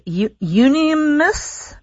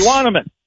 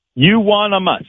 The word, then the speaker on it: euonymus: Is it unanimous bush? – Euonymus. – Say it again. – Euonymus. – Euonymus.